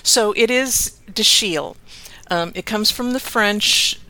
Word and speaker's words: Dashiell: So think of it in the French way, and the C-H is pronounced like Chicago, or the S-H is pronounced like Chicago. But it's Dashiell.